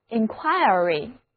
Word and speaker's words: inquiry: 这个时候字母 i 的读音就变成了 ire。 inquiry 意思还是询问、打听、调查。